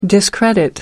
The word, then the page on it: discredit: American English